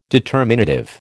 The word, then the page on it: determinative: American English